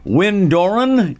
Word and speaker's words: Wyn Doran: Delighted today to have Wyn Doran in studio.